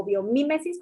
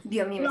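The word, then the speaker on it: biomímesis: Lo que pasa es que traíamos ahí un trip por la oficialmente ya se tradujo como biomimesis con acento en la i, pero yo siempre decía biomimesis, pero ya el Biomimicry Institute dijo, no, es biomimesis y así va a ser.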